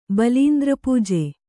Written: ಬಲೀಂದ್ರಪೂಜೆ

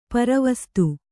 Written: ಪರವಸತೆ

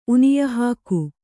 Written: ಉನಿಯಹಾಕು

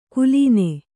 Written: ಕುಲೀನೆ

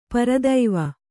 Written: ಪರಿದೇವ

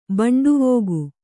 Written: ಬೆಂಡುವೋಗು

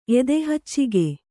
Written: ಎದೆಹಚ್ಚಿಗೆ

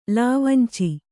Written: ಲಾವಂಚ

